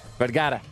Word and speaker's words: Vergara: on the phone with us right now, Modern Family's Gloria Sofia Vergara. I can't say it right. You do it. – Vergara.